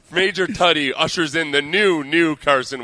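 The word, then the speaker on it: Tuddy: Oh, by the way, it's Major Tutty. It's pronounced Tutty.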